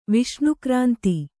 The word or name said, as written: ವಿಷ್ಣುಕ್ರಾಂತ